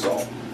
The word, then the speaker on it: Zall: My name is Zal. – Zal? – How do you spell that? – Z-A-L-L.